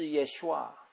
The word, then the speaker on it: Yeshua: 那这个拯救的字呢，就是希伯来文啊， 3444 啊，这个读音就是 Yeshua，拯救就是 Yeshua，拯救也是救赎，也是昌盛，也是帮助，也是胜利，也是医治释放的。